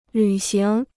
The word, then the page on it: 履行: lǚ xíng